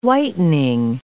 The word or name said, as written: whitening